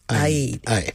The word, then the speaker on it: Eid: I actually looked it up because, in English we say Eid, but in Arabic it's Eid.